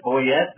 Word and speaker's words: Boyett: Right below he and I is Brett Boyett. That's correct. That's the right way to say that. – Yeah, that's right.